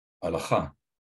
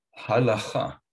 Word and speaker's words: Halakha: Halakha. – Okay, say it again. – Halakha.